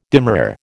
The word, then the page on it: demurrer: American English